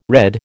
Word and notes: redd: American English

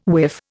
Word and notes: whiff: Indian

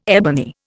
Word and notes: ebony: Indian

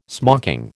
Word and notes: smocking: American English

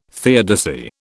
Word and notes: theodicy: British English

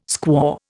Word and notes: squaw: Indian

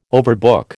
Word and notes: overbook: American English

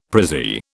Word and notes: prezzie: British English

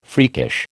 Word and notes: freakish: American English

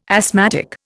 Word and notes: asthmatic: Indian

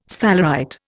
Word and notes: sphalerite: Indian

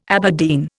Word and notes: Aberdeen: Indian

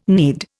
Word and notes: knead: Indian

